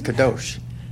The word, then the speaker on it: kadosh: Our word for holiness comes from the Hebrew word kadosh, which means to cut. – Real quick, she said it right. It's kadosh. Yeah, she's good with languages.